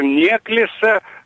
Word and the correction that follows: Неклеса: Неклеса только его фамилия. Александр Иванович Неклеса, у него другое ударение.